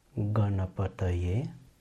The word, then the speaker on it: Ganapataye: In the mantra, it is pronounced as Ganapataye, and then the last word of the mantra is Namaha.